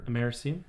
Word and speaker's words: americium: Americium? – Americium.